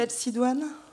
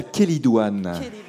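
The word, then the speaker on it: chélidoine: La calcidoine. – C'est la kélidoine. La kélidoine. L'herbe à verrues. Et ça s'écrit CH. Et ça se prononce kélidoine.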